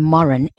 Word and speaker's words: Morin: So it's C-E-L-Y-N-N. And my surname, Morin, M-O-R-I-N.